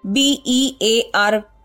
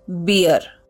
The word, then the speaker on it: bear: B E A R is pronounced as bear, not beer.